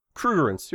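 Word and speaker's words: Krugerrands: Anyway, so he gets away, and they find out the car is full of gold coins, which are called Cougarants. I don't know if I'm saying that right. – Yeah, Cougarants. You're correct.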